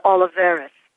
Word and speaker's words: Oliveras: It's it's uh, Oliveras.